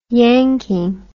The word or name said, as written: yanking